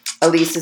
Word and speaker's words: Ilise: Elise is spelled I-L-I-S-E, or pop in your favorite earbuds and listen to me wherever you love listening to your podcast.